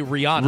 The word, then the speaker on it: Rihanna: How do you pronounce? – Yeah, you know, I uh, so I always say Rihanna. – Rihanna. Everyone says Rihanna.